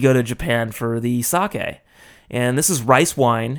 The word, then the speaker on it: sake: As we Americans say, sake. I'd like to try some sake. Sake, as it's pronounced in Japanese.